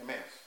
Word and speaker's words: Emmaus: The two disciples who go away, remember they were distraught, the disciples of Emmaus. How do you say it? Emmaus.